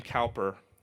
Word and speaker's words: Cowper: William Cowper, I've sometimes heard his name pronounced Cooper before.